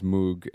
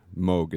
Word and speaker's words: Moog: companies Moog and Moog, it's actually pronounced,